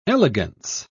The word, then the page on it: elegance: British English